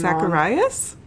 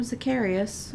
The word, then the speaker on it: Zacarias: So, we got Zacharias, and I'm probably going to say his name Zacharias? – Wrong. – Zacharias? – No, Zacharias. – Okay. – Z-A-C-A-R-I-A-S.